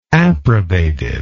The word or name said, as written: approbativeness